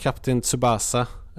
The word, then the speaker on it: Captain Tsubasa: Men vi har spelat Captain Tsubasa Rise of New Champion. – Bra, så uttalas det, officiell uttalning.